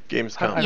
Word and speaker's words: Gamescom: Is that how we pronounce it? Gamescom? – Gamescom. – Yeah, it's com. – With an M at the end, not an N.